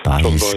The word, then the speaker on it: Baez: Sagt mir dieser Joan Baez oder Baez? – Baez, gell, ja. – So. – Latinisch ausgesprochen. Ja.